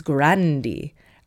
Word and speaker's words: Grande: Apparently, Grande is not meant to be pronounced like it is at Starbucks. It's Grandi. Ariana Grandi.